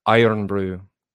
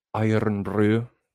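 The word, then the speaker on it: Irn-Bru: Roll your R's when you say brew. Iron brew. Iron brew.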